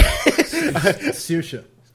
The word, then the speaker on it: Saoirse: How do you pronounce her name? Saoirse Ronan? – Yep. – Get a real name. Anyway. – Saoirse. – Thank you.